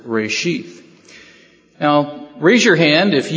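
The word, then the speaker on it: reshith (ראשית): The Hebrew word is reshith, reshith, and it's spelled R-E apostrophe S-H-I-Y-T-H. R-E apostrophe S-H-I-Y-T-H.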